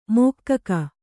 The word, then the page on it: ಮೋಕ್ಕಕ: mōkkaka